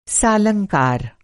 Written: सालंकार

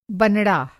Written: बनड़ा

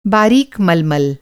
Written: बारीक

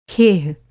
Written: खेह

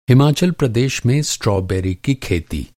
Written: स्ट्रॉबेरी

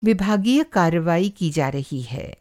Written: विभागीय

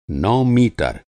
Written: नौ

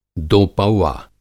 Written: दोपौवा

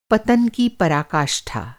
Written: पराकाष्ठा

